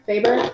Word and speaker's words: Faber: So, and I feel like, how do you say his name? Faber? – Faber, okay.